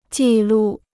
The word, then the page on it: 记录: jì lù